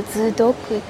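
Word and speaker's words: Sudoku: ドイツ語だとズドクっていう発音になります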